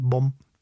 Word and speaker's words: bom: Das M in bom wird eher nasal ausgesprochen, so ein bisschen wie ein sanftes Summen am Ende.